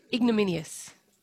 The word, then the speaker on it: ignominious: Ignominious. Is that right? – No. – Not ignominious. – But how do you say it? – Ignominious. – Ignominious. – There's no L. – Minious. Yeah, remove the L.